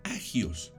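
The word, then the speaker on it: hagios: Y en griego se pronuncia agios.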